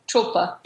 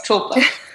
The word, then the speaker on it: tulpa: a more accurate pronunciation would be tulpa? – Tulpa. – Tulpa? – Tulpa. – So it's a tonal language, and I can't really hear it correctly. – Tonal and aspirate.